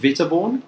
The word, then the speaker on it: Witteborn: Is it Witteborn? – Yeah, very good.